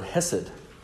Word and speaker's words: hesed: And it's the word hesed, which is just spelled with vowels, H-E-S-E-D.